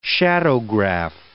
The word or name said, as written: shadowgraph